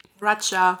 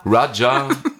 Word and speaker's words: Raya: Wie spricht man R-A-Y-A aus? Raya. – Raja. – Raja.